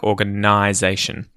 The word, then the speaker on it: organization: And the N-I in there sounds like you're saying the word I, like the I in your head, but with an N in front of it. So, nization. And I think Julia was telling me she had a little bit of trouble with how to pronounce that N-I in words like organization.